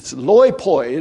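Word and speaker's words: loipoi (λοιποί): It's loipoi. It'd be like if we were going to spell it in English, it'd be L-O-I-P-O-I. Loipoi.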